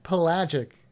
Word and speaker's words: pelagic: They're pelagic. How about that word? Throw that word at you. – That's a big word. – That's a big word. I mean, it's pelagic. What is it? Pelagic. Three syllables.